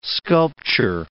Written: sculpture

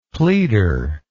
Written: pleader

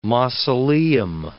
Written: mausoleum

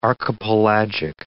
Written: archipelagic

